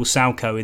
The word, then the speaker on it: Salchow: It's capital S-A-L-C-H-O-W. It's named after an individual who invented the move. So whenever you hear a co-commentator moaning about a striker doing a triple Salco in the area, that's how it's spelt.